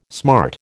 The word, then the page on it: smart: American English